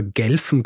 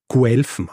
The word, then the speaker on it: Guelfen: Übrigens auch im Nachhinein wurde ich darauf hingewiesen, weil ich immer Gelfen gesagt habe und Ghibellinen. Tatsächlich spricht man es Guelfen aus. Also es sind nicht die Gelfen und Ghibellinen, sondern die Guelfen und Ghibellinen.